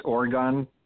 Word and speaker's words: orgone: But there are some people that say that if you use like it's pronounced Orgone technology, O-R-G-O-N-E, that can help disperse the chemtrails or break them up.